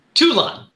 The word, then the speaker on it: Toulon: And he, and then runs in and, you know, Talon. How do you pronounce his last name? Andre Talon, right? – You're doing good. – It's Toulon. – Andre Toulon.